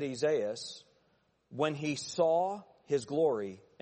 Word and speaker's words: Isaias: Isaias, of course, would be the Greek pronunciation of Isaiah.